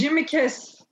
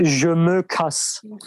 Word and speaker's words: Je me casse: Jamakas diye okunuyor diye biliyorum ben. Je me casse.